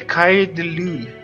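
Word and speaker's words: Écailles de Lune: Mas beleza, continuando na discografia deles, chegamos no segundo álbum que é o Ecas de Lune. Falei certo? – É, segundo a minha amiga, a pronúncia é, é Kaidelu. – Kaidelu, ok.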